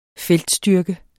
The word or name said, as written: feltstyrke